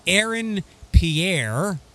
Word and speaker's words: Aaron Pierre: But the point here is, and I mispronounced the name on the air, his name is Aaron Pierre.